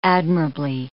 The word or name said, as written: admirably